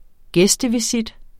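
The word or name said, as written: gæstevisit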